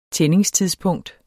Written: tændingstidspunkt

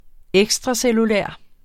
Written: ekstracellulær

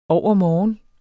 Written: overmorgen

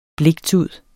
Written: bliktud

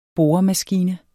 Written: boremaskine

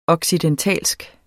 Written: occidental

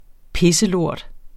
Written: pisselort

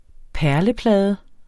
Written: perleplade

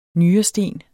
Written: nyresten

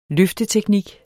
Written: løfteteknik

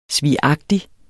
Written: svigagtig

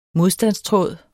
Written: modstandstråd

